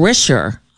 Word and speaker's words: Richer: Stephen Richer, I believe is how it's pronounced. R-I-C-H-E-R is how it's spelled.